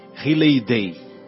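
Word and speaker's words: Riley-Day: E é muito é, Halley Day. Como é que se escreve? R-I-L-E-Y. Riley. Riley Day.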